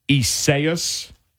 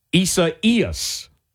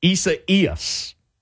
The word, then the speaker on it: Isaias: Some say Isaias. It's not that either. Here's how to say that name. Isaias. Isaias. The accent goes on the second E, which is the I, and you say Hurricane Isaias.